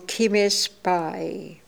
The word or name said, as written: Kimespai